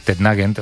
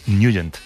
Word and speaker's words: Nugent: Hombre, siempre lo hemos llamado en el barrio Tetnagent o Tetnagent. – Claro, ¿no? Yo era de Tetnagent. – Pero la pronunciación correcta es Nugent.